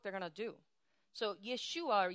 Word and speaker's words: Yeshua: So Yeshua is the original pronunciation in Aramaic, which was the language that they spoke in the time of Yeshua and before and after.